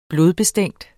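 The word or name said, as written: blodbestænkt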